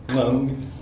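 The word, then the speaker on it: !Kung: It's actually pronounced kung. It's like a clicking sound, but for presentation purposes, I'll just say kung.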